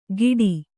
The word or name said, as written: ಗಿಡಿ